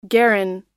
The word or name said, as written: garron